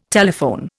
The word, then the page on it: telephone: Indian